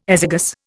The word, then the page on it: azygous: Indian